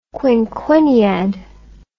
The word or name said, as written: quinquenniad